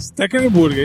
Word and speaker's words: Stekelenburg: Ah, o Stekelenburg. – Stekelenburg, é isso aí mesmo. Vou pedir um na padaria. – Pô, bom goleiro, bom goleiro o Stekelenburg.